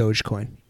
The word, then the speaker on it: Dogecoin: Dogecoin.